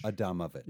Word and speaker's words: Adamovich: Andamovich? – It's Adamovich. – Adamovich. – Yeah. It's okay. Spelled like Adamovic, like Adam Ovic, but it's Adamovich.